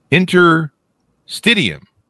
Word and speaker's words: interstitium: Through his scientific research, he is a pioneer of adult stem cell plasticity and the anatomy of the human interstitium. Did I say that right? – Interstitium. – Interstitium.